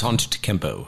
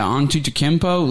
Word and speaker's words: Antetokounmpo: Giannis Antetokounmpo. – Yeah, Antetokounmpo, like his, what was it, game two and all the way till it was over, he was fucking on it.